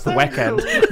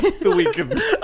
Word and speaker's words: The Weeknd: I think it's pronounced The Weeknd. Ladies and gentlemen, The Weeknd.